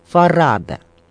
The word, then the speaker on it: Фарада: Между прочим, многие ставят ударение в фамилии Семен Фарада на последний слог, тогда как сам актер утверждал, что это ошибка, и ударение должно приходиться на второй слог, то есть Фарада.